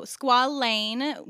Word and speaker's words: squalane: So squalane, which is spelled like Penny Lane with an A, is the ingredient we like.